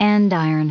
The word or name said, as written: andiron